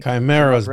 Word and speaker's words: chimera: Crimea is a country, right? – Yeah. – That's what I mean. Chimera.